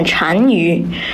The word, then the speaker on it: 单于: 月黑雁飞高，单于夜遁逃。欲将轻骑逐，大雪满弓刀。 – 读得可真有气势，那这首诗有哪些需要我们注意的地方吗？ – 我发现了这首诗中有一个多音字单，在这里念单于。